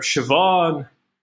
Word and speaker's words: Siobhan: Um, you don't have any of the like Caoimhes, which is spelled with a C and M and a H, um, or Siobhan, or Saoirse.